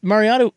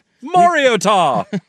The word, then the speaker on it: Mariota: Well, we thought Mariotta, oh my God. – Mariota would be easy, and he butchered that. This is a top two pick. – Mariotta.